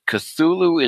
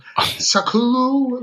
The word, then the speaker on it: Cthulhu: Yep, you pronounced it right. Though I was just in an interview where they did not. They were Sakulu, like they kept saying it very weird.